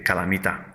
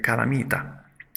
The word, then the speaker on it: calamità: È costretto a lasciarla a causa di una guerra, ad esempio, o di altre calamità, come appunto un terremoto. Una seconda parola che ho appena usato è calamità. Calamità. Attenzione, calamità si scrive con l'accento. Calamità all'accento sull'ultima A. Altrimenti sarebbe calamita, che ha tutto un altro significato.